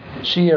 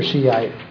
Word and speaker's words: Shia: Shia or Shiite, again, Shia is kind of the newfangled pronunciation and spelling.